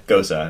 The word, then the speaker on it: Gose: I was like, actually, fun fact, I had to look up how to pronounce Okay. – Gosa, and it's Gosa.